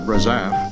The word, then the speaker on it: Razaf: Shortly after that, his friend Andy Razif, or a lot of people pronounce it Razaf, wrote lyrics to Ain't Misbehavin'.